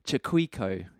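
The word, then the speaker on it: Chaquico: Craig Chiquico, his name is.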